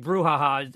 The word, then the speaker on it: brouhaha: What do you make of this brouhaha? – No, I think brouhaha, emphasis on the first syllable, is probably where we're at here.